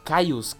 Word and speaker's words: Kyuss: Y después en el tiempo a mí me hablaban harto de la banda Cayus, que es con K-Y-U-S-S.